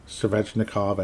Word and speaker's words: Svechnikov: You have a really nice goaltender in Frederik Anderson, Sebastian Aho, Svechnikov, I actually said it correctly, woohoo, Teravinen, obviously phenomenal, obviously great player.